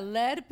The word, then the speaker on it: lead: No, y lead se pronuncia LED, pero también existe LED, L-E-D. – Exacto, L-E-D, que es otra cosa.